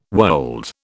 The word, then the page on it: wold: British English